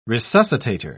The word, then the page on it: resuscitator: British English